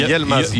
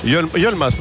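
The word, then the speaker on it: Yılmaz: Est-ce qu'on ne doit pas dire Yilmaz alors, puisque le I se prononce E, donc exact. On a Y-I, donc c'est Yilmaz. – Yilmaz. – Yilmaz, pas Yel.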